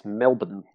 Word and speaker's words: Melbourne: Melbourne, and you say Melbourne. So we gotta. – Yeah, work on that too. Say it like this, Melbourne. – Melbourne. – Melbourne. – Melbourne. – That's it. Okay. Now you got it.